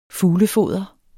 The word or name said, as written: FUGLEFODER